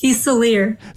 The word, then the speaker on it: Fiselier: Meantime, Dr. Anna, can you pronounce your last name for me so I don't butcher it? – It's Fiselier.